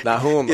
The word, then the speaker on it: Nahum: How do you say it? Nahum? Nahum. Nahum. I speak English. We say Nahum. Okay, Nahum. – In that book. – It is Nahum. It is probably Nahum, because I have to look at the Hebrew and see what the vowel pointing is on that.